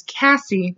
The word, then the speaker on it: Casey: 17 and a half years later, after her abduction and murder, that she has another sister whose name is Cassie, but it's spelled like Casey.